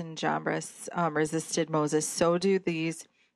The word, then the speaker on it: Jambres: now is, how do you pronounce it? – Jannes and Jambres. – There you go.